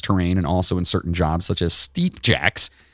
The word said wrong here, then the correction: steeplejack: It's steeplejack. First of all, I mispronounced it. Steeplejack.